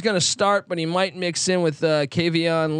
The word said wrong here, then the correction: Kevon: Lee, or if I'm pronouncing that correctly. – Kevon.